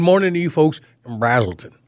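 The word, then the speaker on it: Braselton: I got to say it right. And Brazelton. – And Brazelton. Yeah, you say that right now. – I did just say it right, didn't I? – Yeah.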